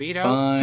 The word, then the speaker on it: Kibito: Especially Kibato. – Oh, but can one of you say his name right once? Just Kibito? Just once. – Kibito! – Kibito! – Kibito! – Kibito. – It's Kibito.